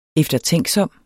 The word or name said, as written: eftertænksom